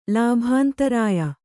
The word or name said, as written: ಲಾಭಾಂತರಾಯ